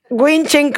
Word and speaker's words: Wing Chun: El Qigong sexual, Sifu en Wing Sun Quen Kung Fu, ¿lo dije bien? Wing Chun Kung Fu, dentro del linaje del Sifu Carson Lau.